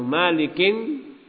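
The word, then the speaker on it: Mālikin: Walidhalika yakra'u Malik bitan yukra'u Malik bitanwin. Nah, oleh karena itu An Abdullah ibnu Malikin nam dibaca dengan tanwin.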